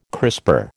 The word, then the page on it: crisper: American English